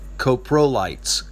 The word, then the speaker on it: Coprolites: I always say it wrong. I say copalites, but it's really um, Coprolites.